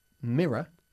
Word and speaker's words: mirror: Mirror. – El espejo. – Y se pronuncia mirror, como si acabase en una A, ¿ok?